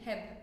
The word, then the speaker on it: Hebb: What's his name? Hebbian? – Hebb, yeah. And how do you spell it? – H-E-B-B.